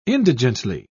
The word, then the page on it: indigently: British English